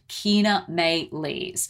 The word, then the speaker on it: Chaenomeles: So if you've seen this and you're like, I don't know how to pronounce it, it's spelled C-H-A-E-N-O-M-E-L-E-S, and that is pronounced Kina Maylis.